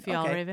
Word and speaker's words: Fjällräven: Fjällräven.